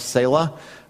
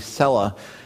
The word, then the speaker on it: Selah: They call themselves Selah. The more accurate biblical or Hebrew pronunciation is most likely Selah.